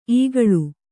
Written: ಇಕ್ಕಳು

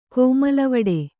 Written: ಕೋಮಲವಡೆ

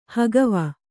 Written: ಹಗವು

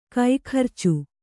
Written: ಕೈಗರ್ಚು

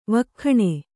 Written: ವಕ್ಖಣೆ

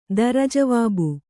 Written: ದರಜವಾಬು

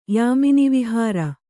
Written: ಯಾಮಿನಿವಿಹಾರ

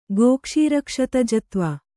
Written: ಗೋಕ್ಷೀರಕ್ಷತಜತ್ವ

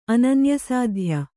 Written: ಅನನ್ಯಸಾಧ್ಯ

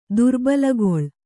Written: ದುರ್ಬಲಗೊಳ್

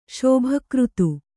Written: ಶುಭಕೃತು

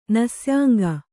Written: ನಸ್ಯಾಂಗ

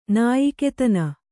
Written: ನಾಯಿಕೆತನ

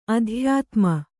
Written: ಅತ್ಯುತ್ತಮ

